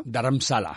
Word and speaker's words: Dharamsala: Y, entonces se creó en, a, ver si lo digo bien, Dharmasa se llama. Dharamsala.